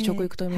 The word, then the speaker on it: 食育: まずはそもそもこの食育、まあ、食べる育つと書いて食育と読みますけれども、はい、食育とはどんなものなのか牧野さん教えていただけますか。